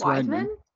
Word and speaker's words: Weidman: How do you say his name? Weidman? – Weidman? I think it's Weidman.